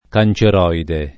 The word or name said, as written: canceroide